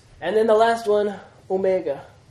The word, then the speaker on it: omega: O as in tone. It's the long O. O. Omega. – O. – It's O. Mega. Remember how O. Micron. Small O. And O. Mega. Big O.